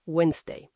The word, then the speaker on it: Wednesday: Se pronuncia Wednesday, sin la D, esa del medio. – Efectivamente, esa D es muda.